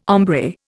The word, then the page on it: hombre: Indian